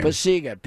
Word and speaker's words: Pacyga: It's Ryan Pasiga is his name. – Pasiga, P-A-C-Y-G-A.